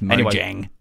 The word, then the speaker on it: Mojang: It's Mojang.